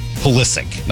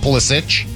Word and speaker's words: Pulisic: Pulisic. Not Pulisic. – No. – Hard C. Looks like it should be Pulisic.